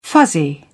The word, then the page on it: fuzzy: British English